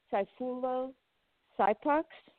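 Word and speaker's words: Sayfullo Saipov: It was a man by the name of Saifulo Saipax. – I know if I'm saying his name right. You did a great job pronouncing his name.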